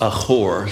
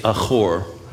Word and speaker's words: achor: That word in Hebrew is achor. Somebody say achor. And you got to do the ch. Achor. If you're writing it out in English, phonetically, I guess it would be A-C-H-O-R. But when you see that in Hebrew, it's achor.